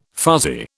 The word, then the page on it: fuzzy: British English